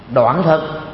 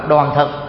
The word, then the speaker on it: đoàn thực: thực phẩm nó gồm có 4 loại: đoạn thực, hay còn gọi là đoàn thực, nó tùy theo cái phát âm, đoàn thì đúng hơn, đoàn nghĩa là tròn.